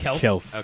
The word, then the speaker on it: Kelce: that the official pronunciation of that name is Kelce.